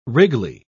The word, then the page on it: wriggly: British English